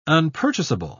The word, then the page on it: unpurchasable: British English